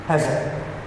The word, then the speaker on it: Hesed: Spelled like this, H-E-S-E-D. Hesed.